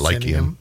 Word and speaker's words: Lyceum: Which was at the I don't know if I'm going to pronounce it right or not. – Lyceum. – Lyceum. – Lyceum. – Lyceum. – Lyceum. – Okay. What he said.